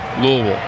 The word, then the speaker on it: Louisville: Zach McCambly, who pitched yesterday, was sent back to Louisville. Did I say that right, John? – You did. – Louisville.